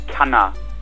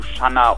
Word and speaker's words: Channa: Ähm, das Wort Shanna kommt eigentlich eher aus dem griechischstämmigen und müsste richtigerweise Kanna ausgesprochen werden, also mit dem K, ähm, wird aber eigentlich von niemandem so ausgesprochen, sondern äh, wurde irgendwie im Sprachgebrauch zu Schanna umgewandelt.